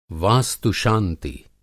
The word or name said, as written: वास्तु-शांति